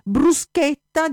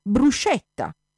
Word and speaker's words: bruschetta: Sarebbe stato come, che so, come un tedesco che invece di pronunciare bruschetta dice bruscetta, oppure come un italiano che invece di pronunciare Eichhörnchen dice Eichhörnchen.